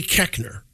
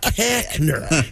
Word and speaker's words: Koechner: Nowhere in the English language does the O-E make the short E sound. K-O-E is not Koechner. It's at least it's Koechner. Kohler toilets. Come on, gang.